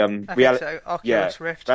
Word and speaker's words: Oculus Rift: Innovation of the year was the Oculus Rift. Is that how you pronounce it? Yeah, that's the Oculus Rift.